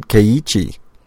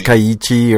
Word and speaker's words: Keiichi: Keiichi. – You just hold your eye a little longer. Because it's technically two syllables. – Yeah, it's Keiichi or Keiichi, that's okay.